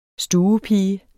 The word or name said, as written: stuepige